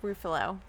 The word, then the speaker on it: Ruffalo: Ruffalo.